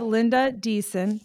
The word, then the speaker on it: Linda Deason: Today we have Linda Deason.